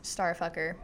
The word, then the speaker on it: STRFKR: It's just letters. – But it's pronounced Starfucker.